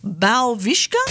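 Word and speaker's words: Białowieża: Now, Steve explored one of Europe's last primeval forests, and I'm going to have a go at pronouncing this place in which he went biking with bison, Balvishka. Yeah, that's pretty good. – That is uh. pretty good.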